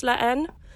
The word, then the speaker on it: La Haine: Um, so if you do do a part two, um, please just know that it's a silent H when you pronounce La Haine.